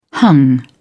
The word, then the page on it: hung: British English